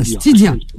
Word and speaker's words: Stidia: Comment ça s'écrit? Comment ça se dit? – S-S-T-I-D-I-A. – Ah, Stidia. D'accord.